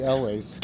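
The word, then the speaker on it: Elwes: Gary Lewes. How do you say that? – Gary Elways. – Gary Elways. God, what is that, Scottish?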